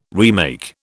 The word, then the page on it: remake: British English